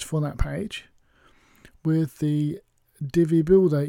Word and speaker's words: Divi: one of their themes like Divi, D-I-V-I. I keep saying Divi, D-I-V-I. It's pronounced Divi, but, you know, just to make that clear.